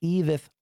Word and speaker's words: Edith: It's not Ivith. It's Edith Luna, an amazing woman of God.